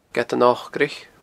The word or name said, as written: Gead an Ocraich